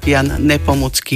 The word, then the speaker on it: Jan Nepomucký: Náš stály poslucháč Tomáš Lučenca sa pýta, ako sa vyslovuje meno českého svetca mučeníka. Povedala som, že je český svetec a mučeník, preto Jan Nepomucký.